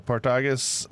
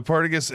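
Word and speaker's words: Partagás: It was the Partagas? – Yes. That's the way I say it. Wow, Partagas. – I put the emphasis on the wrong syllable there. the Partagas Añejo Esplendido.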